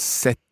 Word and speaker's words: cette: It becomes set. – Set. Okay, it sounds like number seven, but it's spelled. – C-E-T-T-E.